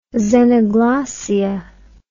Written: xenoglossia

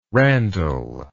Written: randall

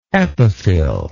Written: epiphylline

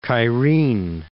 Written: Cairene

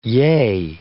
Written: yea

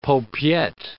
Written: paupiette

